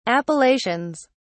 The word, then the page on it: appellations: British English